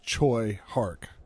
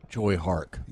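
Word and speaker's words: Tsui Hark: I've been assured that the correct pronunciation is Choi Hark. – Choi Hark.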